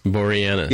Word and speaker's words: Boreanaz: Boreanaz.